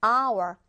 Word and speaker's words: hour: H로 시작하니까 요거는 자음일 것 같아요. 어. 이렇게 가면 안 됩니다, 여러분. 발음이 기준이 돼야 돼요. hour, hour. 아 발음으로 시작하고 있죠? 발음이 모음으로 시작하니까 on hour이라고 적어줘야 됩니다.